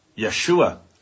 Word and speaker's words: Yeshua: We think the correct pronunciation of Jesus' Hebrew or Jewish name was Yeshua.